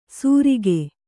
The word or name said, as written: ಸುರಿಗೆ